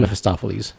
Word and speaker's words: Mephistopheles: Mephistopheles. – Mephistopheles.